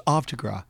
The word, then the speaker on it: Havre de Grace: It's pronounced Havre de Grace. – Yeah. – Wait, is that for real? – Yeah. It is for real.